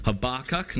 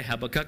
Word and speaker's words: Habakkuk: And so our journey this morning is in the book of Habakkuk. Now, again, I looked up how to say this. Most of us say Habakkuk, but apparently that's the most wrong way to say it.